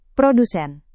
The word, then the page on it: produsen: Indonesian